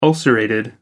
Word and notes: ulcerated: American English

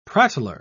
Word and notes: prattler: British English

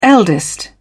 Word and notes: eldest: British English

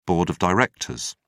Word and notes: board of directors: British English

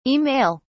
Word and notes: e-mail: British English

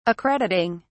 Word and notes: accrediting: British English

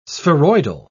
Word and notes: spheroidal: British English

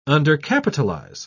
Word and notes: undercapitalize: British English

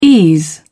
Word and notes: ease: British English

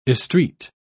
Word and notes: estreat: British English

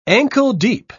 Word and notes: ankle-deep: British English

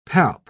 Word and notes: palp: British English

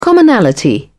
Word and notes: commonality: British English

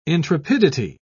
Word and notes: intrepidity: British English

